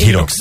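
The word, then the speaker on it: HYROX: Ezt így hívják, ez a sportágat, hogy Hirox? – Szerintem hívjuk így, nálunk az még annyira... – Magyarországon nem... én eddig csak így hallottam kiejtve. – Hogy Hirox.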